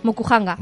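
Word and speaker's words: Mokuhanga (木版画): Eh, lo he dicho bien, Mokuhanga. – Mokuhanga.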